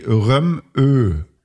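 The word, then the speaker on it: Rømø: Der dänische Inselname Römö wird ungefähr wie Römö ausgesprochen. Also das Röm, ja. Doppel M, so ausgesprochen. Und mit einem, ja, Bindestrich ÖH, also mit einem langen.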